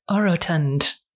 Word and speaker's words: orotund: All right, Dave, let's turn to our words of the episode, and I will go first, and I'm going to say it like the lady on Merriam-Webster, orotund. Orotund.